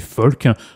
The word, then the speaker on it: pholque: On va parler du folk, rien à voir avec la musique, avec le F-O-L-K. Rien à voir avec ça, ça s'écrit P-H-O-L-Q-U-E, tout simplement.